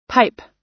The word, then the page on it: pipe: American English